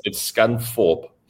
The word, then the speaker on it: Scunthorpe: No, but it's Scunthorpe. You don't pronounce the TH, it's with an F.